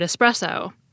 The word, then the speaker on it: espresso: But it's a thing that I know irritates a lot of people, so it's best to stick to the espresso pronunciation, unless your goal is to annoy people.